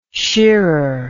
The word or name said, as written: shearer